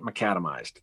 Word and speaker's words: macadamized: Am I saying that right? Yep, macadamized. So, yep.